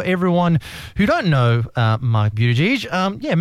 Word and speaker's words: Buttigieg: I hope I have pronounced your name properly, Buttigieg. – That's the Maltese pronunciation. Yes. A lot of people say Buttigieg, but it's actually Buttigieg is the correct pronunciation, so well done.